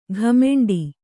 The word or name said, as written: ಘಮೆಂಡಿ